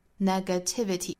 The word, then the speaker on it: negativity: 一般来说，三个音节以上的词，如果单词尾部是以 y 结尾的，它的重读音节呢会落在倒数第三个音节，所以是 t 这个音节。我们数一下，哒哒哒哒的 negativity 就落在了 t 这个位置上。